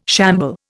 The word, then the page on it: shamble: Indian